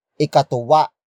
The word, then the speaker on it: ikatuwa: First, we have dapat ikatuwa ang Pasko. Dapat ikatuwa ang Pasko. Dapat ikatuwa ang Pasko. It means Christmas should make you joyful. The focus is on Christmas. Word for word it's dapat ikatuwa ang Pasko.